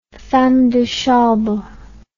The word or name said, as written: femme de chambre